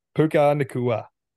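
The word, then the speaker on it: Puka Nacua: Puka Nakua.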